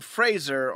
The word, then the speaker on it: Fraser: We had Brendan Fraser on the episode earlier.